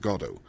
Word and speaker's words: Godot: The only thing he insists on is that it's pronounced Godot. And he said, with a twinkle in his eye and a wink at the questioner, because it sort of sounds like God.